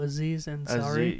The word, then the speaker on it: Aziz Ansari: Azu Aziri or something? – Oh, Aziz Ansari? – Yeah. I fucking totally butchered his name.